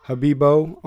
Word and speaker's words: Habibeaux: You can follow along with Habibo on Instagram, H-A-B-I-B, and then that Louisiana way, E-A-U-X. – Yeah, A-U-X, the X, yeah, is silent.